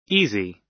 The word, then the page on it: easy: American English